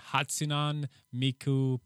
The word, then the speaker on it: Hatsune Miku: And then Hatsune Miku Project Diva Mega Mix for the Switch.